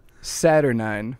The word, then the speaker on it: saturnine: Okay, Saturnine. – Saturnine? Saturnine. Oh, no. S-A-T-U-R-N-Y-N-E. – So close. – Is it I-N-E? – It literally is Satter, like Saturday, and nine, like I tried to get fancy on it.